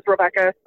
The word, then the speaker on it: Rebekah: You can find me on Twitter under Becca, B-E-K-A-H, Boodles, B-O-O-D-L-E-S. That's my handle on Twitter. And I'm also on Facebook under my name, which is Rebecca, spelled the same way, R-E-B-E-K-A-H, Stark, S-T-A-R-K-S.